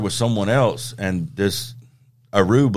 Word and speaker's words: Aroo: And it's spelled A-R-O-O. But it says on his page it's pronounced Uproo. – I always pronounce it Uproo.